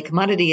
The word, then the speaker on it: CMDTY: So commodity, and by the way, we should say commodity is spelled CMDTY, the company name is, correct? – That's correct.